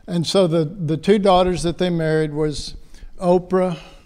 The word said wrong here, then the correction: Orpah: Sorry, mispronounced that. Orpah.